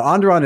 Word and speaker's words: Onderon: Onderon. You got it. – Okay. In the Jaeperiel system, an Alliance intelligence had learned of a secret Imperial outpost deep, deep in the jungle named Whisper Base. Now, Onderon is actually home to a very large Imperial garrison that's under the command of one Admiral Corlan.